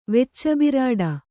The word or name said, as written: ವೆಚ್ಚಬಿರಾಡ